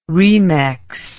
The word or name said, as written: remex